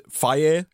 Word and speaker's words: Fage: I get Fage is how they pronounce it. F-A-G-E is the brand of the yogurt. I really like it. It's good. It's plain yogurt. But they pronounce... It says in the box, it says pronounced Fage. And so, I think it's like Fage, but it's pronounced... It's spelled F-A-G-E.